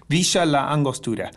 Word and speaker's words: Villa La Angostura: Villa La Angostura.